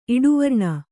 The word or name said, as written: ಇಡುವರ್ಣ